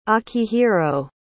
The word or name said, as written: akihiro